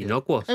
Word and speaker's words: Inocuos: Inucuos o como se pronuncia. – Inocuos.